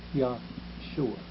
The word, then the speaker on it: Yahshua: And the Greek word is merely a rendition of the Hebrew Yahshua, which is the same as Joshua, except that the Hebrew pronounce it Yahshua.